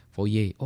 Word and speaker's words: Fourier: Fourier. pas Fourier transform. Ah. Salah Pak itu. Uh, Fourier bukan salah juga gitu apa foye oh gitu bacanya foye